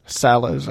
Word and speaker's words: de Sales: D. Silas. – Salas. It's spelled sales, but I don't think it's pronounced that way because it's French.